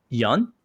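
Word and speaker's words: Yeun: I keep getting caught on him because he was on Conan once and he said my name is pronounced Yun. Yun. And I'm like, even my Korean friends are, no, I think it's Yun. It looks like Yun.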